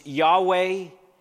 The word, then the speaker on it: Yahweh: In Hebrew, it consists of just four consonants. The best guess at how it ought to be pronounced is Yahweh.